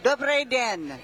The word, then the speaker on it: Dobrý den: Dobrý den. Good day. – Dobrý den.